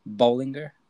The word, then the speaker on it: Bolinger: I am here today with Dr. Jesse Bolinger. Did I pronounce that correctly? – Nice job.